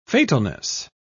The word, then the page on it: fatalness: British English